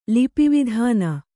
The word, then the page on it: ಲಿಪಿವಿಧಾನ: Kannada